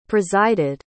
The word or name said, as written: presided